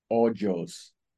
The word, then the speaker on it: Hoyos: My last name is really easy to mess up. It's pronounced Hoyos.